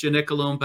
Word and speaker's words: Gianicolo: It's on the Gianicolo Hill. It's impossible for Americans to pronounce Gianicolo. They call it Gianicolo. But in English, it's Gianicolum.